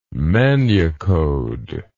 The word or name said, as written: manucode